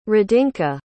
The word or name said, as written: radinka